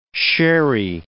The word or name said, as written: sherry